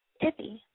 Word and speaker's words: Tiffy: This is Tiffy. – Wait, what is your name? – Tiffy. – How do you spell it? – T-I-F-F-Y.